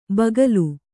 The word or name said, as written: ಬಗಲು